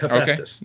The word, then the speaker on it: Hephaestus: Am I pronouncing that correctly? – That is correct, Hephaestus.